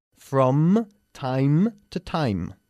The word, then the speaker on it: from time to time: Con lo cual es muy importante no olvidar cerrar la boca al pronunciar el sonido de la M en inglés, lo que no se hace en español. From time to time.